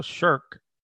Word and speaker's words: shirk: Mr. Rashid, is the non-Trinitarian Jesus shirk, hope I pronounced that well, shirk, S-H-I-R-K.